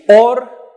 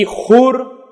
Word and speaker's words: Ωρ: Έτσι, Ορ ή Χούρ, ανάλογα με το πώς θα προφέρει κανένας το αντίστοιχο εβραϊκό όνομα.